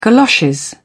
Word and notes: galoshes: British English

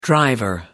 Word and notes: driver: American English

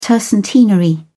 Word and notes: tercentenary: British English

